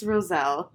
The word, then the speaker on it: Roselle: How do you say it? – I don't know. Roselle? – Okay. – Roselle? Roselle. – It's Roselle. I know it is.